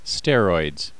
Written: steroids

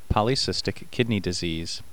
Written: polycystic kidney disease

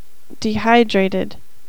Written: dehydrated

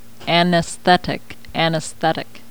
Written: anesthetic

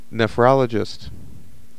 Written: nephrologist

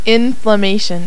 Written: inflammation

